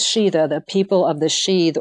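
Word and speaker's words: sídhe: And in early Irish sources, it was referred to as the realm of the sheath, and that's spelled S, I with an accent, D, sheath.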